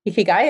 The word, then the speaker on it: ikigai: Ikigai, am I pronouncing it right? – Yep, ikigai.